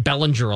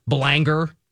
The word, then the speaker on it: Belanger: You think Belanger is hard to pronounce? – Yeah, I get Bellinger a lot. Belanger.